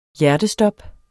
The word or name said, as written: hjertestop